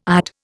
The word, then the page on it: ide: Indian